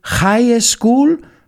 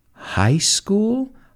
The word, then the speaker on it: high school: Is it high school? Con esa HJ fuerte, high school? No. Is it high school? Suave y uniéndolo como una sola palabra, high school, high school.